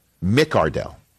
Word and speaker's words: McCardell: Close to the fact that Keenan McCardle came out and said, no. Excuse me. I am Keenan McCardell. McCardell.